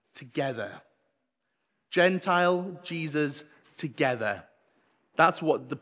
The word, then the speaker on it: Gentile: Looks a bit like Gentile. Gentile's how it's said.